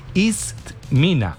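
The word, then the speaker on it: Itsmina: Vea, se escribe así, se escribe como ella dijo. Itzmina, así se dice.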